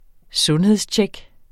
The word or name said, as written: sundhedstjek